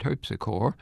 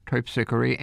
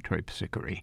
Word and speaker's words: Terpsichore: Of course, we have a street here in New Orleans named Terpsichore, which has its own pronunciation. Can't argue with that. But the composer is Michael Pretorius, and his dance collection is called Terpsichore, and the muse of the dance is called Terpsichore.